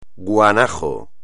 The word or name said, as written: guanajo